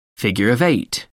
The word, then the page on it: figure of eight: American English